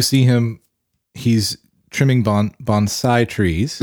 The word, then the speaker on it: bonsai: A, he makes a big point to make sure that you pronounce, pronounce it, right. correctly. Yeah, bonsai. Which yeah.